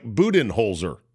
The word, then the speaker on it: Budenholzer: Mike Budenholzer.